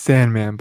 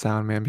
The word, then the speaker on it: Soundman: It's Sandman, but actually it's pronounced Soundman because, yeah, then we get what his stand is.